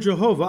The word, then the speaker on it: Yahweh: probably pronounced Yahweh, but we Gentile Christians pronounce it Jehovah for most of the time. But which is why the word Jehovah, I Am, is often coupled with a noun.